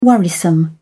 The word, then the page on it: worrisome: British English